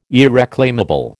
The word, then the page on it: irreclaimable: American English